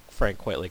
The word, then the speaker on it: Frank Quitely: is it Frank Quitely? Is that how you say his name? – Yeah.